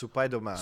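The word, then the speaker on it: Supaidoman (スパイダーマン): But it was basically the Japanese pronunciation of Spider-Man. So it's Supaidoman.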